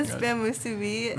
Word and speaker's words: Spam musubi: The spam musubi. Mm-hmm. Like the, I don't even know how to pronounce it right, but. – Spam musubi. Musubi. Yeah.